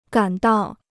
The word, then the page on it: 赶到: gǎn dào